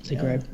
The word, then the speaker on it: Zagreb: Scroll down and tell you this now, because the 15th of September, Stewie Ramsden and Gregson are doing a 1500 in Zagreb. Is that how you pronounce that? – Yep, Zagreb. – Yep. Got that right, Brady.